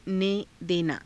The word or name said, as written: ne_denaa